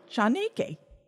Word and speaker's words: Chaneque: It's spelled Chanequee. And then I wrote in parentheses. I remember I looked it up. I wrote Chanake. – Okay. – Chanake.